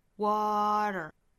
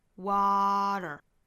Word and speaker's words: water: 在美音里也很常见这个 flap t 和卷舌的这个连在一起，所以会有一个 utter 这样的发音， water, water, water, 这样去练习。